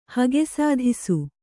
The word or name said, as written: ಹಗೆಸಾಧಿಸು